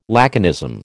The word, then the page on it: laconism: American English